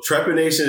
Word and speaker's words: trepanation: So trepanation is the practice of drilling holes in the skull to alleviate certain ailments.